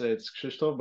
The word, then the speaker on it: Krzysztof: It's Krzysztof, but it's all good.